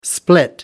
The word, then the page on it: split up: American English